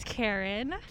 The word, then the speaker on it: Karen: My name is Karen.